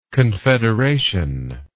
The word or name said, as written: confederationist